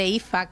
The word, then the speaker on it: Aoife: El nombre Aoife, que se pronuncia Aoife, se escribe Aoife.